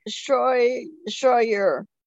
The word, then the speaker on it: Scheurer: Well, it's Scheurer. – And Scheurer, like a oh my. And, okay. – It doesn't even phonetically, you know, come out like it looks on paper.